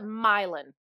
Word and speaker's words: Milan: It's called Milan, Tennessee. It's spelled like Milan, Italy. So I never. – And they call it Milan.